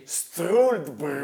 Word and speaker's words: Struldbrug: Me lo ridici? – Struldbrug. Vedi come lo pronuncia bene.